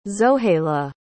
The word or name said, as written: zohayla